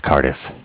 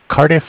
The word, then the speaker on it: Cardiff: This is Bank of Cardiff. – Okay. I'm sorry. – Bank of Cardiff. It's spelled C-A-R-D-I-F-F, like car diff.